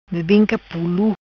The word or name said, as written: Bebinca Pulu